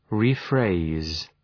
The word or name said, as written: rephrase